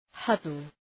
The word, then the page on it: huddle: ‘hʌdəl